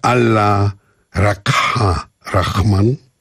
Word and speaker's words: Allah Rakha Rahman: To się pisze A.R.Rahman przez samocha. Wymawia się Allah Rakha Rahman.